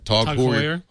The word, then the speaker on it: Tag Heuer: Tag Heuer.